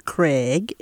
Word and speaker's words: Craig: I'm My name is Gwen Craig, and if it's spelled later, it's G-W-E-N-N, Craig, C-R-A-I-G.